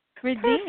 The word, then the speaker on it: Ridini: Ridini.